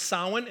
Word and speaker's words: Samhain: It's actually spelled Samhain, but it's pronounced Samhain.